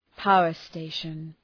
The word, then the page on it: power station: ‘paʋər,steıʃən